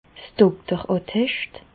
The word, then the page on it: 's (Dokter) Attescht: Bas-Rhin; Reichshoffen